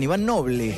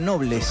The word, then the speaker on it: Iván Noble: Lo dije bien, Iván Noble. Tiendo a decirle Nobles.